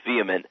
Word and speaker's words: VHEMT: So VHEMT, pronounced vehement, is a movement, not an organization.